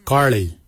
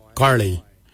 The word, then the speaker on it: giilyi: Darb is spelled D-H-A-A-B. It is a traditional knife, a darb. For boomerang, we say karli. Karli is the traditional name for boomerang. Karli is spelled G-I-I-L-Y-I, karli. For the traditional stone axe, we say goj, goj. Goj is spelled G-O-O-D-J, goj.